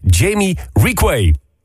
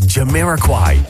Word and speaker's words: Jamiroquai: Ik kwam er laatst pas achter dat ik de complete jaren 90, mijn favoriete artiest, verkeerd heb uitgesproken. Ik vertelde namelijk iedereen op het schoolplein dat ik fan was van ene Jamie Requay.